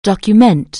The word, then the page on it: document: anglais